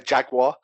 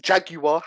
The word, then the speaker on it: Jaguar: It's like when some Americans say Jaguar, there's no W. – Yeah, Jaguar. Yeah, I get it. – It's Jaguar, how it's spelt.